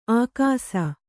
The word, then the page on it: ಆಕಾಸ: ākāsa